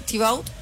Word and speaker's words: Thibaut: Sí, Thibaut. – Thibaut. – Thibaut. – Thibaut. – Thibaut Courtois.